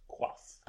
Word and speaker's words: coif: I did not know that C-O-I-F was pronounced quaff.